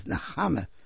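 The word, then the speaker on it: Nechama: My Jewish name was Nahama. – Can you spell that, please, for us on tape? – N-E-C-H, because there's a chai in Latvia.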